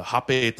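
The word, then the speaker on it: rapé: It's a hape tobacco, oh. But it's spelled rape.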